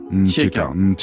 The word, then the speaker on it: Ntiekar: Dinos, on va écouter Ntiekar, toujours extrait de votre album Taciturne. – Il faut pas prononcer le N. – Ah, faut pas dire le N. – Ntiekar.